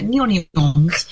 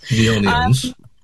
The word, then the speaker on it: Neon Eons: We've written the name Neon and then Eons, Eons, so that it sounds a bit like onions when you say it. Neon Eons.